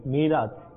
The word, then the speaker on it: মিরাজ: আচ্ছা, আসলে নামটি হয়তো বিশুদ্ধ উচ্চারণ এটি হবে মিরাজ, মিরাজ যেটা হুজুর পাক সাল্লাল্লাহু আলাইহি ওয়াসাল্লাম মেহরাজ শরীফে গিয়েছেন, মিরাজ শরীফে গিয়েছেন, তো আমরা এটাকে মিরাজ বলে থাকি, সবে মিরাজ বলে থাকি, তো এটা মেহরাজ নয় মিরাজ।